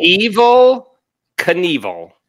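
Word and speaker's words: Evel Knievel: Evil Knievel.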